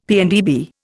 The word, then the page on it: PNdB: Indian